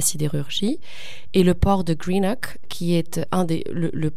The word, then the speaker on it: Greenock: Alors Airdrie, Coatbridge et Greenock. C'est ça, je les prononce sans doute assez mal, mais je vais vous laisser le faire peut-être. – Non, non, c'est très bien prononcé.